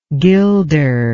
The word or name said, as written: gilder